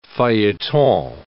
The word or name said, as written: feuilleton